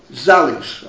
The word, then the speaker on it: Zalis: Ah, the Zalis maybe, I'm pronouncing it wrong. But what when? Zalis, am I pronouncing it right? Anyone? Which is it? – Zalis. – Okay, stand corrected. An A instead of the U.